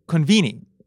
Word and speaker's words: konbini: Konbini, konbini, if I guess we made it to English. Konbini, which is four syllables, is actually short for? – Convenience store.